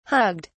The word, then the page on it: hugged: British English